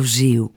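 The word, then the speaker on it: Gil: Η μία και μοναδική Βραζιλιάνα φίλη που έχω μου έλεγε πάντα ότι το λάμδα στο τέλος των λέξεων προφέρεται ου. Ναι, ναι. Οι Βραζιλιάνοι που μιλάνε Πορτογαλικά δεν λένε Μπραζίλ, αλλά Μπραζίου. Και εδώ πέφτουμε ακριβώς πάνω σε αυτή την περίπτωση, διότι φιλοξενούμε τον ταλαντούχο κύριο Ζιλμπέρτο Ζίου.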